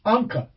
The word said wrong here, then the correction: Enka: Oh, Enka. – Sorry. Enka. Bad pronounce.